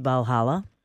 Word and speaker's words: Valhalla: Let's talk about Assassin's Creed Valhalla. It just had its world premiere. Pronounce that right? – You did. You did very well.